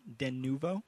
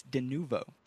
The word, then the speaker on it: Denuvo: The official pronunciation, Denuvo. Matt and I have this problem all the time. We always say Denuvo. – Denuvo? Denuvo? – It's Denuvo.